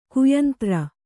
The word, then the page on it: ಕೈಯಂತ್ರ: kaiyantra